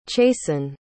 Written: chasen

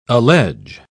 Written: allege

